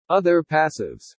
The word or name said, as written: OTHER PASSIVES